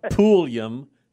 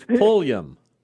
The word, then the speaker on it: Pulliam: It's not Pulliam. It's Pulliam. – Pulliam, yeah. – There you go. Well, that makes sense since it's spelled P-U-L-L-I-A-M.